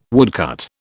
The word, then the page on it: woodcut: British English